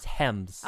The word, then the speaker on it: gems: It's pronounced hems. – Oh, hems. Okay, okay. Hems.